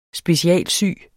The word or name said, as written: specialsy